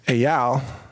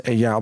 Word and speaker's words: Eyal: And specifically, one friend I have from Israel from many years ago, his name is Eyal, or E-Y-A-L. I think you would tend to pronounce it Eyal, but some people say Eyal.